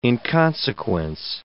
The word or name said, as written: inconsequence